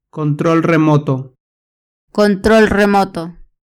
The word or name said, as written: control remoto